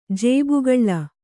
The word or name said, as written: ಜೇಬುಗಳ್ಳ